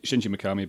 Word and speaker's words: Shinji Mikami: Shinji Mikami? – Shinji Mikami, basically.